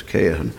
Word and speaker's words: Cajetan: He first debated a cardinal, Cardinal Cajetan, it's spelled Cajetan, but it's Cajetan, and he was steeped in Roman Catholic tradition, and he was simply citing papacy, papal decrees, and councils.